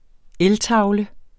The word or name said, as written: eltavle